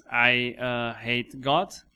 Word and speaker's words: I Hate God: Estem xerrant de la banda, crec que molta gent coneixerà, que és I Hate God, tot junt, que no s'escriu així com se pronuncia, sinó que és I de ui, no de jo.